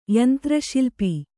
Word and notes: ಯಂತ್ರಶಿಲ್ಪಿ: kannada

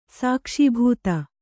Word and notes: ಸಾಕ್ಷೀಭೂತ: Kannada; sākṣī bhūta

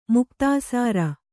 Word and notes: ಮುಕ್ತಾಸಾರ: muktāsāra; Kannada

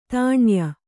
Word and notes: ತಾಣ್ಯ: Kannada

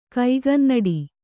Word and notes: ಕೈಗನ್ನಡಿ: kaigannaḍi

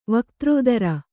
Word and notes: ವಾಗ್ದರಿದ್ರ: kannada

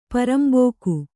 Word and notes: ಪರಂಬೋಕು: Kannada

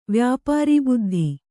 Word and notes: ವ್ಯಾಪಾರೀಬುದ್ಧಿ: vyāpārī buddhi

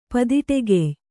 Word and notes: ಪದಿಟೆಗೆಯ್: padiṭegey

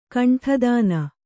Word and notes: ಕೊಂಡಿತನ: Kannada

